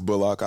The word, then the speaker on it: Bullock: It was always Bullock. I was just letting people usually pronounce it how they want to pronounce it. I mean, if you're from the country, but I mean, my family is from the country, so the younger people in my family usually pronounce it Bullock.